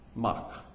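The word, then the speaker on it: Mach (מק): Now, one of the words for rotten or bitter or stinky is the word, let me make sure I spell it right, it's spelled a mem kof. Mem kof. Mach.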